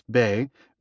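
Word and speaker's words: bei (貝): The radical Chinese character, pronounced bei, means shell or currency, and it even looks like one of the cowries.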